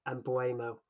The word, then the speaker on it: Mbeumo: I don't even know who the player is, but I'm going to guess it's Mbeumo. – That's it. I think that was quite a good pronunciation, actually.